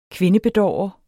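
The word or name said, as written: kvindebedårer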